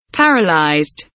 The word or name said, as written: paralyzed